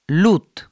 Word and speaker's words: loot: Se pronuncia loot, se escribe con 2 O, y alude a saqueo, al acto de saquear.